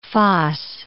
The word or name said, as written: fosse